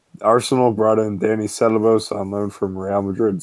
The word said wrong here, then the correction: Ceballos: Mate, your pronunciation is beyond shocking. – Ceballos. Dani Ceballos. – Or Ceballos, depending on how American you want it to be.